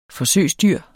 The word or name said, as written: forsøgsdyr